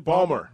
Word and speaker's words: Ballmer: And Steve Ballmer, is that the way you say his name? – Ballmer.